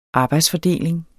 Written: arbejdsfordeling

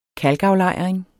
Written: KALKAFLEJRING

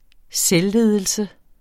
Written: selvledelse

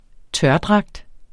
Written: tørdragt

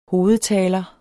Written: hovedtaler

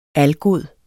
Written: algod

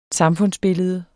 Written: samfundsbillede

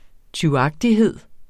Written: tyvagtighed

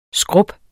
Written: skrub

